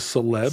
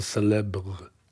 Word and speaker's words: célèbre: Did you just gently pronounce the R in cause célèbre? – Célèbre.